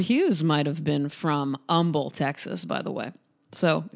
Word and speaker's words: Humble: It was in a town called Humble. That's how they pronounce the word H-U-M-B-L-E there in Texas, just to give you an idea.